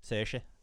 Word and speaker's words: Saoirse: Saoirse, like an Ayrshire.